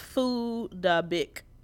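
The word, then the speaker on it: Phuc Dat Bich: It's pronounced Foo Da Bic.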